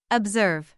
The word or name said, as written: observe